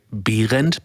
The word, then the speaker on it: Behrendt: And if you spell it correctly in German, it would say Behrendt, but nobody in, you know.